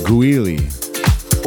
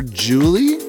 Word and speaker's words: Guili: The track is called Guili. – Or Julie? – G-U-I-L-I.